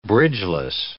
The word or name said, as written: bridgeless